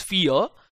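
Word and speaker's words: Fe: I mean, that's what people are still debating over, what's the pronunciation of the name of the game. The publishers, the developers say it's Fear, but a lot of fans call it either Fae or Fee.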